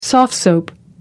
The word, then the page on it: soft-soap: American English